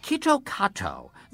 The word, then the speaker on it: Kit Kat: Kitto Kato, the Japanese pronunciation of Kit Kat, sounds an awful lot like Kitokatsu, which in Japanese means you surely will win.